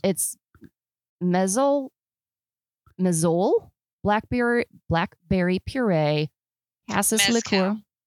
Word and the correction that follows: mezcal: Oh, mezcal.